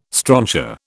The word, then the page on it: strontia: British English